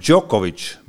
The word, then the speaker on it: Djokovic: häälduspilt on Djokovic, ei ole seal mingit jotti. esiteks no,.